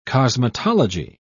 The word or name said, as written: cosmetology